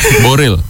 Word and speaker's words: Boreal: 0,74 triliunnya berada di wilayah Boreal. Boreal suka-suka ya. – Boril. – Boril. Oh, bacanya Boril.